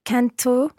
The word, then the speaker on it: canto: Canto, canti, canta, cantiamo, cantate, cantano. – Almost perfect. Well done. Just watch the stress of canto, canti, canta, the stress on the first syllable there.